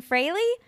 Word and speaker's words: Fraley: We are here with Jason Fraley.